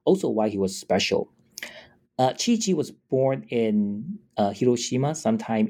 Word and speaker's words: Chiichi: Chiichi is spelled C-H-I-I-C-H-I, a blind male musician of Heike who lived from the early 18th century through the turn of the 19th century.